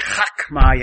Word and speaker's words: chokmah: When you say chokmah, you have to chok, like you gotta clear your throat. That's how the Hebrew hey is pronounced. It's a guttural. It's called a guttural.